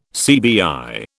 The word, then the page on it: CBI: British English